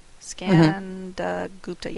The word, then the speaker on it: Skandagupta: Skandagupta, yeah, I think I said that right. I've heard Jim Wise say the name. That's the only reason why I know how to say it.